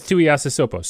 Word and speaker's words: Tuiasosopo: Marcus Tuiasosopo. So, I mean, like. – Good pronunciation, sir.